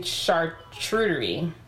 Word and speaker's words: charcuterie: Yeah, we, we were saying charcuterie, right? Charcuterie, but chartreuse is a color.